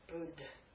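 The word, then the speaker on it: budh: Bud. Okay? You ever see, this is a short u, ba, short u, bud.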